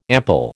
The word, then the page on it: ample: American English